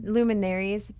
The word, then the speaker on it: luminaries: And also on our ladies' retreat, side note, made these, are they called, I'm going to butcher it, luminaires? Luminaries? – Luminaries.